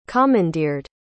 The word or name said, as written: commandeered